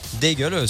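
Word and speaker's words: Daigle: Lorraine d'aigle ou Lorraine d'aigle, comme vous voulez. – Oui, voilà, d'aigle, c'est sa prononciation en anglais, on en est désolé.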